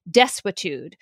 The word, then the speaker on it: desuetude: I should start by saying that there is a doctrine, and I looked up how to pronounce it. It's called desuetude, for not enforcing very, very long dormant statutes.